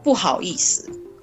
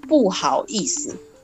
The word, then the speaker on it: 不好意思: It and pronounce as 不好意思.